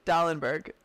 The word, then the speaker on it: Dalenberg: Dalenberg.